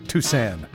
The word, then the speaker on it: Toussaint: Because on his early recordings, it's Al T-O-U-S-A-N. Like a phonetic spelling. Like, in other words, you don't say Toussaint or Toussaint or Toussaint. Hence my pronouncing it Toussaint.